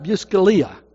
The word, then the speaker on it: Buscaglia: Leo Buscaglia, did I say that right? Buscaglia is actually his name, said unexpressed love is one of the greatest causes of sorrow and guilt.